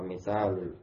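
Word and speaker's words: misal (مثال): Wa mislu aqdi al-imanu billahi wa malaikatihi wa misalul qaul wa misalul amal wa misalul aqd. Itu bacanya misal. Kalau dia ada alif bacanya misal, ikut wazan fi'al.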